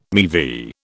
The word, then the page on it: MeV: British English